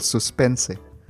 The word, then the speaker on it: suspense: Suspense, como la palabra en inglés, pero con una pronunciación en español, suspense.